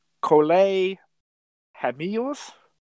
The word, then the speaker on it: Cole Hamels: Cole Hamels.